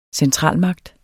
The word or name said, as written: centralmagt